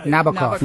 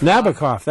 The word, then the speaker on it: Nabokov: Is that Nabokov. – Nabokov. That's yep. How it's pronounced.